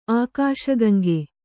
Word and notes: ಆಕಾಶಗಂಗೆ: Kannada

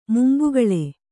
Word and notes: ಮುಂಬಾಗಿಲು: mumbāgilu

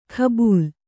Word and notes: ಕಬೂಲ್: kabūl

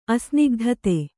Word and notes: ಅಸ್ನಿಗ್ಧತೆ: Kannada

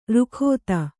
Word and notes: ರುಖೋತ: rukhōta; kannada

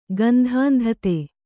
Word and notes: ಗಂಧಾಂಧತೆ: kannada

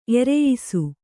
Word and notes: ಎರೆಯಿಸು: kannada

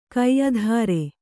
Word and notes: ಕೈದಾರೆ: kaidāre

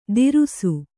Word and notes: ತಿರುಸು: Kannada

